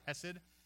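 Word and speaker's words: hesed (חסד): It actually is spelled in like a lot of the Strong's concordances, C-H-E-C-E-D. And when you say hesed, you're supposed to say it with a little bit of phlegm at the beginning.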